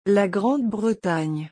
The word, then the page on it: La Grande Bretagne: French